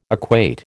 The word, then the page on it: equate: American English